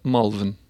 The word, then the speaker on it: Malvern: Secondo me è più comune la pronuncia Molven. Malvern. Però mi ricordo anni fa era abbastanza diffusa la pronuncia Malvern.